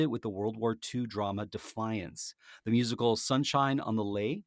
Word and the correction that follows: Leith: it's Lay, right? – On Leith. – Leith. Oh, it's Leith, pronounced Leith.